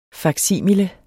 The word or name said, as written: faksimile